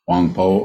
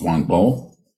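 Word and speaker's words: Huang Po: This evening's Dharma talk is titled, what's after something, the ninth century Chinese monk, Wang Po, or sometimes pronounced Wang Bo, said, the ninth century, mind is no mind.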